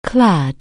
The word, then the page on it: clothe: anglais